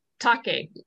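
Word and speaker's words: take (竹): take? – Yes, take. It's the Japanese word for bamboo.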